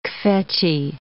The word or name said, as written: kvetchy